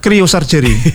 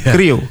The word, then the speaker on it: cryosurgery: Bacanya gimana Pak? – Cryosurgery. Kryo. Kryo itu saya takut baca, makanya saya takut bacanya cry bukan ya? – Kryo. – Cryosurgery.